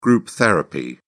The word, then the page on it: group therapy: British English